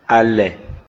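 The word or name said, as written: Alɛ̀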